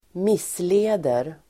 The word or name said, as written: missleder